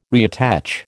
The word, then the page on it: reattach: American English